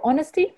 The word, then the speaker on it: honest: Because, of course, the word honest has a silent H.